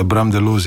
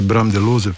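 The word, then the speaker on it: Bram De Looze: Robert Jukić kvartet in Bram Delos, sem prav prebrala, mi je izgovorila pravzaprav ime tega izvrstnega belgijskega pianista. – Perfektno, glede na to, da je moj priimek tudi, oziroma vsi doživimo izgovorjave, interpretacije naših priimkov na različne načine, tako da Bram Delos je absolutno perfektno, drugače Bram Delose, pa tudi to nisem prepričan, če prav vem. Ja, novembrski koncert, zanimivo, da je Congregation skupaj v, kako se tem reče, skupnost v Cankarjevem domu na Martinovo 2025.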